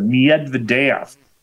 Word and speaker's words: Medvedev: The reason why I struggle is I asked a Russian waitress who was a good friend of mine in a local restaurant, how do you pronounce her name? And she was Russian. She said, Medvedev.